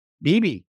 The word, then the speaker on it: Beebe: So, you know, like Anatole Levin, who's been on the podcast before, and George, does he pronounce it Beeb or Beebe? – Beebe.